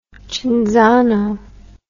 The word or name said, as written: Cinzano